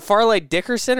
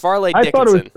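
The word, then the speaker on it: Fairleigh Dickinson: Is it Farley Dickerson or Farley Dickinson? I keep getting it wrong. It's Farlane Dickinson.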